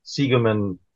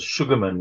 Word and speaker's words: Segerman: my surname, Segerman, was always mispronounced as Sugarman, which was a much more common surname in Johannesburg.